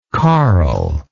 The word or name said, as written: carlishness